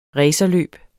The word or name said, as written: racerløb